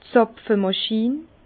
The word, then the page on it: d' Zàpfemàchin: Bas-Rhin; Herrlisheim